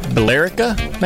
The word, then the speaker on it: Billerica: How do you pronounce that? Billerica. There's an E in there. – Billerica. Billerica, maybe? – Billerica. – Oh, that makes sense. Just leave out the E.